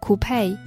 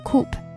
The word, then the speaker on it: coupe: 然后有一种比较酷炫一点的车，它叫做 coupe 或者是 coupe，这个词两个发音都可以，然后一个是 coupe 一个是 coupe，这种就是双门跑车。